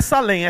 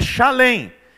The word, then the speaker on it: Salém: Na verdade, a pronúncia não é Salém, é Shalém, que é derivado de Shalom, que significa paz.